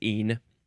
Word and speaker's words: -ene: E-N-E again, uh, but this one's the suffix. Now, how do you pronounce this? Um, yeah, just een. Yep.